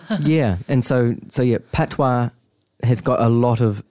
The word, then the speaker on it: patois: language is English, but most of the population speak produce the patois? – Patois, yeah. – Patois, yeah. Pronounced patois. – Yeah. Yeah, Jamaican patois. – Patois. Oh, Jamaican patois, sorry. – Yeah. – Jamaican pronounced patois.